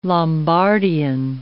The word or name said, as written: Lombardian